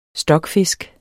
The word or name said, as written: stokfisk